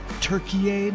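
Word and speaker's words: Türkiye: Now, I know I'm supposed to pronounce it Turkey now, but I'm having a hard time with that because I feel like I'm just saying Turkey with a Canadian accent.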